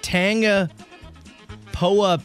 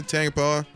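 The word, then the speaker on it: Tangipahoa: Okay, let me spell it for you, okay? – Yeah. – T-A-N-G. – Yeah. – I-P-A. – Yeah. – H-O-A. And yeah. – then Parish. – Yeah, yeah, Tangapoa. Tangapoa. – Tangapoa.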